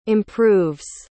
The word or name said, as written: improves